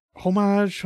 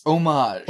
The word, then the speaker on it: homage: I know, but I can't say the word, because, like, you don't pronounce the H in that word, or you do, but it's weird. – Can you put it in the Discord so I can pronounce it? – Homage.